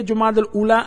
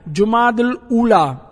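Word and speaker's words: جمادی الاولیٰ: صحیح نالو این صحیح تلفظ لغت جے اعتبار سا انن بینی مہینن جا صحیح این درست تلفظ ہی آہن جماد الاولا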